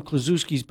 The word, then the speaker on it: Kaczynski: And I knew some Kaczynskis, but I don't know if they're... I don't know if they're related. – Kaczynski is how you pronounce it.